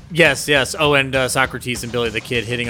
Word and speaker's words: Socrates: It's pronounced Socrates. – So, oh, yeah, Socrates.